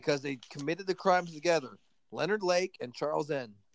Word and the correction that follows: Charles Ng: First of all, it's pronounced Charles Ng.